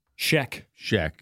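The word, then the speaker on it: Schaech: Remember, we were talking about Roadhouse one day, and Jonathan Skitch. – Sheck, God. – It's spelled weird. – I know, it's totally spelled weird.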